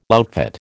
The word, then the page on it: outfit: American English